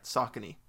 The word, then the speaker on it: Saucony: And how I discovered Saucony and pronounced it wrong all the time and probably still am. So. – No, I think you're right. Saucony.